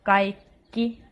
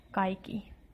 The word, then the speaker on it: kaikki: One more thing is that sometimes we do have double consonants, for example, a double K, and then it is important to pronounce both of them. So, for example, in the word kaikki, it is indeed kaikki and not kaikki.